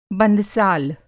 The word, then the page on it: बंदसाल: Hindi